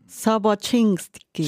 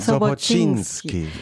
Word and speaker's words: Soboczynski: Wenn man ganz korrekt wäre, würde man äh, sagen Sobocinski. Sobocinski. Cinski, genau. – Sobocinski.